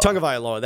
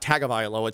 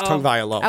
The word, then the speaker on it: Tagovailoa: Tagovailoa. That's how you actually pronounce it. Not Tagovailoa. It's Tagovailoa.